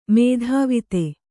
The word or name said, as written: ಮೇಧಾವಿತೆ